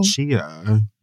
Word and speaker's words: Tchia: Oh, Chia. It's on PlayStation. – Chia. – Oh. It's spelled T-C-H-I-A. I think you will love it. – T-C-H-I-A.